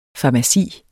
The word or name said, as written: farmaci